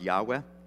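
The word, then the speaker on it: Yahweh (יהוה): Now, right up the top is, is actually a Hebrew word pronounced Yahweh, is God's name.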